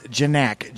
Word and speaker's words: Janack: It's pronounced Janack. J-A-N-A-C-K.